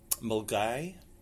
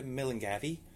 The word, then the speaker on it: Milngavie: My favourite one in Scotland, or in our local area, is Mulgai, which is spelt Milngavie. So a lot of people go, do you know the way to Milngavie? And I'm like, no, what? Mulgai. It's pronounced Mulgai.